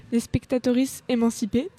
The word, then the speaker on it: Les Spectatrices émancipées: Du coup, en rappel, on était du coup ici pour le spectacle Spectatoris émancipé. Je vais le redire hein, parce que je ne l'ai pas très bien prononcé, les spectatrices émancipées.